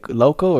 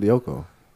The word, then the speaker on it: Lyoko: How do you say, Loco or Lyoko? – It's called Lyoko.